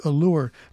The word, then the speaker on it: Elour: It's pronounced Allure. Let me ask you this question. You can be honest with me, all right? You have a band, you call it Allure, but you spell it E-L-O-U-R, knowing that everybody on this planet's going to say Allure, is not going to say Allure.